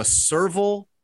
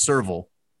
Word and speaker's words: serval: I think it's pronounced serval. S-E-R-V-A-L is what I have.